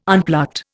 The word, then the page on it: unplucked: Indian